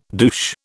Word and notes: douche: British English

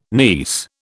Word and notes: niece: British English

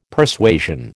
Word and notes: Persuasion: American English

